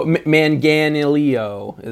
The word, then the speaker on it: Manganiello: Also, Joe Manganiello is in it.